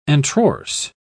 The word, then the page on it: antrorse: British English